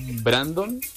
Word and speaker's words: Brandon: Hola, Laurita, ahorita que están hablando de nombres, fíjate que yo me llamo Brandon, que en Carlos. La mayoría de personas me dice Brando. – Ay, no. – O Brandón. Brandona. Y aparte, Giancarlo se escribe J-E-A-N-C-A-R-L-O.